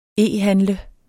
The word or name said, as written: e-handle